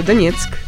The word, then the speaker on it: Donetsk: Maria é da cidade de Donetsk, que eu devo ter pronunciado errado. – Não, você pronunciou muito certo, Donetsk.